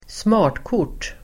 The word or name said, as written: smartkort